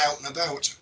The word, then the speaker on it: Alt and About: it's spelt like a Scottish place name, but it's pronounced more or less out and about.